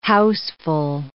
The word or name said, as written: houseful